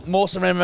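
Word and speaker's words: Mawsynram: Mawson Ram in Meghalaya. – Oh, Mawson Ram in Meghalaya.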